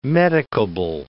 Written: medicable

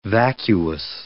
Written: vacuous

